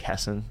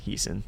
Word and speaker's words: Hessen: And then Sarah, her last name is pronounced like Hessen, but people on the show say Heeson.